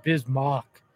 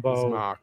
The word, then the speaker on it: Bismack: It always throws you, though, because it's spelled Mack, M-A-C-K. Yeah. So I feel like that's why people always get confused, because when they're looking at the name, they're going to pronounce it kind of like that. But it is Bismack.